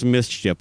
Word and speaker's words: MSCHF: Uh, the the the company is called like I think it's pronounced mischief, but it's it's spelled M S C H F.